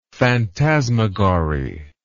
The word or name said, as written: phantasmagory